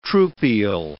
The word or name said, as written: troupial